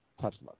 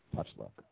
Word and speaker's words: Tochluk: Renegade, and our guest, Dr. Uh, Shelly Tochluk. Did I pronounce the name correctly? – Yes. – Outstanding. Doctor, I forgot that early. Doctor Shelly Tochluk.